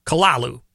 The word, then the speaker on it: Cthulhu: Made this monster called the Kalalu. – Yeah. – That's how it's pronounced.